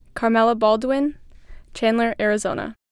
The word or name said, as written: Carmella Baldwin